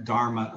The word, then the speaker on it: dharma: It's pronounced dharma, D-H-A-R-M-A. There's a more common word that's similar from Buddhism called dhamma, D-H-A-M-M-A.